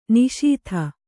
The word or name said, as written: ನಿಶೀಥ